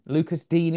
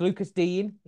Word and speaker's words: Lucas Digne: I tweeted it out, because there's been quite a few ways, and it's Lucas Digne. Lucas Digne is the way we say it. That's the way he pronounced it.